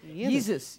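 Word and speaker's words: Yeezus: Yesus.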